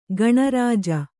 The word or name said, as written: ಗಣರಾಜ